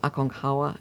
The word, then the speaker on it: Aconcagua: Yes, but I think the locals, it's more Aconcagua.